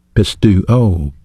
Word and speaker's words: pisteuo: You know, faith is a noun, and in the Greek language it's pronounced pistis, P-I-S-T-I-S. It has a verb counterpart, and that's the word believe. Pistuo, P-I-S-T-E-U-O.